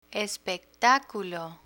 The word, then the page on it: espectáculo: Spanish